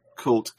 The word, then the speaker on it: Cult Games: A lot of people say KVLT games, and I mean, that's fine too, but it's intended to be pronounced cult. – Okay. Well, I should introduce you. – Like in black metal, you know, true cult.